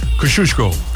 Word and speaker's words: Kosciuszko: So it's Kosciuszko, but you say it. – Kosciuszko. – Because that's how you're supposed to pronounce it.